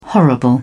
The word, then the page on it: horrible: British English